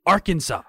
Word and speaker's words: Arkansas: English isn't the primary language of the person in the TikTok, and she's like, how is this Kansas, and this is our Kansas, but you pronounce it Arkansas.